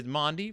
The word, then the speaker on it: Maundy: I understand that it is a word, as she says, is Maundy from mandatum, meaning command, the command to love each other.